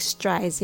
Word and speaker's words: stride: Let's break it down to each sound. S, s T, T, R, R, st, R, stir, stir, stride, stride.